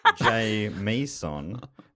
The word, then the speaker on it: Jamison: In brackets, he's written pronounced Jay Meeson.